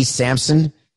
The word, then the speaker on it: Samson: By the way, my last name is spelled S-A-M-S-O-N. I get a few people who write, so I want to talk to Sampson, and I really don't know who they're talking to.